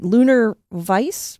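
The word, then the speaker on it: Lunar-VISE: Well, I think you're working on, and make sure I'm saying this right, Lunar Vice mission, is it? – Yes.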